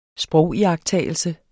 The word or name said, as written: sprogiagttagelse